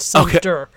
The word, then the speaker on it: Sumpter: You know, Kevin, with his last name pronunciation, just so we don't get hit on by the Tika Sumpter camp. It's Sumpter.